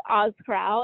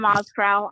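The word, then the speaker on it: Ozkural: Well, you can find me Sam, and then my last name is Ozkral, and I'll spell it for everyone because it's Turkish, a little crazy. It's O-Z-K-U-R-A-L. And you can, I'm the same on all platforms, Sam Ozkral on Instagram and YouTube and Pinterest.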